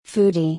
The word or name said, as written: foodie